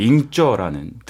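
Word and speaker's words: 잉저 (硬座): 잉저라는 등급과 그다음에 루안저, 네. – 잉어, 루안어. 제가 발음이 괜찮나요? – 네, 좋아요.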